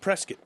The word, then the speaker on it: Prescott: Why do they pronounce it Prescott? – They don't know how to read.